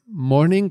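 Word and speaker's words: Mourning: Resulta que Chris Cornell lo quería llamar Morning, que se pronuncia igual, pero con O-U, que significa luto.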